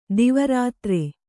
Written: ದಿವಾರಾತ್ರಿ

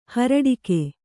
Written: ಹುರುಡಿಗ

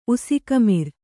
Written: ಉಸಿಕಮಿರ್

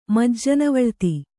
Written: ಮಜ್ಜನವಳ್ತಿ

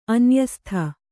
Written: ಅನ್ಯಸ್ಥ

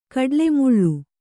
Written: ಕಡ್ಲೆಮುಳ್ಳು

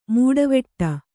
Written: ಮೀಟುವಡೆ

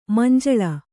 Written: ಮಂಜುಲ

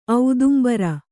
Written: ಔದುಂಬರ

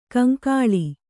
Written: ಕೌಂಕುಳ್

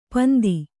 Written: ಪಿಂದು

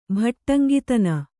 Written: ಭಟ್ಟಂಗಿತನ

